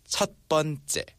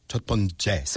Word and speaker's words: 첫 번째: 첫 번째. – 첫 번째.